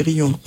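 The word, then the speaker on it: Riom: Ça, je crois qu'on prononce comme ça. C'est pas Rion, hein, c'est Rion. Dans le Puy-de-Dôme.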